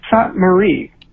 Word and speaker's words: Szatmári: I've asked them to, like, really coach me on the pronunciation. And so I believe that this is the very best I can do at it. It's Szatmári.